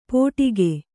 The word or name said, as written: ಪೋಟಿಕೆ